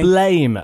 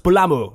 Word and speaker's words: Blame: Blame, uh, which is apparently pronounced "Blamu" like that. So it's more like Blam than Blame.